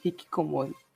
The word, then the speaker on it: Hikikomori: Hikikom, Hikikomorino? Não lembro. É um nome complicado, mas que é basicamente a junção dos dois. A ah, Hikikomori é esse nome. Hikikomori. Falei errado. Hikikomori.